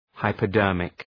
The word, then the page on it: hypodermic: {,haıpə’dɜ:rmık}